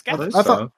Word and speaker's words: Gatiss: Because Mark Gatiss is so good at what he does. – Is that how his last name is pronounced? – Yeah, it's Gatiss.